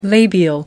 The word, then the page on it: labial: American English